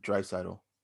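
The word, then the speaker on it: Draisaitl: Dreisaitl.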